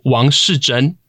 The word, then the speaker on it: Wang Shizhen: so, the National Palace Museum, or NPM, I guess, has recently opened up a new exhibit on Wang Shizhen. Am I saying that correctly? – Yes, very okay. correct.